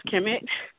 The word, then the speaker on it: KMT: My last name is pronounced Kemet, but that's common. – Kemet, okay. – It's common that people say KMT.